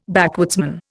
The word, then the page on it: backwoodsman: Indian